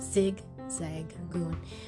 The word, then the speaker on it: Zigzagoon: That's proper way to say their name. Zigzagoon.